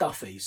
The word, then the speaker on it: Daffue: He's spelt D-A-F-F-U-E, so you may pronounce him Daffu. I'm reliably informed it's pronounced Duffy, so let's go with Duffy for now, and if I get proven wrong, then I'll correct that the next time I put him up in one of these podcasts.